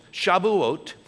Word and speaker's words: Shavuot: Did I say it correctly? Yeah, he's Jewish. Shavuot. Shavuot is the Hebrew word for weeks. Weeks, okay?